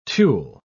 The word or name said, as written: tulle